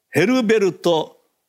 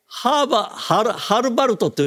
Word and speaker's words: ヘルベルト: 彼のメールの中に、世界的に、そして日本でも有名な、アメリカ生まれのオーケストラの指揮者、ドイツ人ですが、アメリカ生まれのドイツ人、ヘルベルト・ブロムシュテット、これ難しいね、ブロムシュテット、ね、これでハルバルトという読み方もある、まあ、ヘルベルト・ブロムシュテットだけは覚えてください。